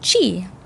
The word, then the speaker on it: qi: In traditional Chinese medicine, the body's broken down into systems of energy pronounced qi and spelt Q-I, which maybe you've seen around.